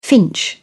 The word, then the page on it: finch: British English